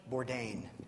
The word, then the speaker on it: Bourdain: Bourdain, thank you.